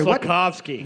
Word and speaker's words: Slafkovský: Sorry, Slafkovský.